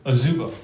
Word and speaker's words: Ozebo: It rhymes with gazebo. – Ozebo? – Ozebo.